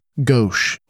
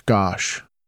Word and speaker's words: Gosch: Last week, I kept pronouncing the last name of Johnny as Gosch, when it is in fact Gosch.